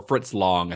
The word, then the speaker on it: Fritz Lang: He worked a little bit later, because, like, M was Fritz Lang's, or Fritz Long, I think is the actual pronunciation, but M is his first sound film.